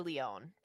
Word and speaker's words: Lyonne: I love me some Natasha Lyonne. That's how you pronounce her last name, right? – Yes.